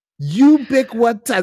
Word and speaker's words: ubiquitous: You got to say the damn word right, man. You listen here. Look. You picture the bouncy ball. Ubiquitous. Ubiquitous. There's four syllables, man. It's like bisquick. Say bisquick.